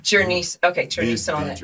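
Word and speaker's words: Jurnee Smollett: Journey Smollett.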